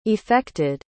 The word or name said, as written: effected